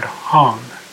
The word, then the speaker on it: Hong: As you breathe in, I'd like you to mentally chant the word Hum, as in Hong Kong, H-O-N-G.